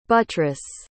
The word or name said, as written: butrus